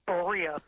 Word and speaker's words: Berea: I'm going to say Berea, Ohio. Did I pronounce that correctly? – It's Berea.